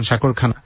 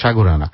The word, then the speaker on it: সাগরানা: আর এটা যারা উত্তর লিখেছ তাদের মধ্যে থেকে অনেকেই সাগরানা লিখছে, আপনি বলছেন সাগরখানা, ব্যাপারটা হচ্ছে সঠিক উচ্চারণটা বোধ হয় সাগরানা,